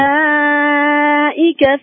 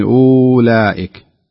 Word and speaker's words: أولئك: والصحيح أن لاحظ الواو عليها حركة زي الحاء الصغيرة أو الدائرة المفتوحة، هذه معناها أنها تكتب ولا تنطق، وهذا ما يسمى بالرسم العثماني، تكتب ولا تنطق. ما يصلح نقول أولئك، الصحيح أن نقول أولئك كأنها من موجودة نهائيا، كأنها همزة مضمومة، أولئك أولئك.